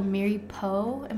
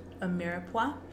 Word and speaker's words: mirepoix: A mirepoix? Am I saying that wrong? – A mirepoix. – Mirepoix. – There you go.